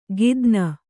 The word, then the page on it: ಕಿತ್ತಾನ್: kannada